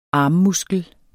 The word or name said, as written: armmuskel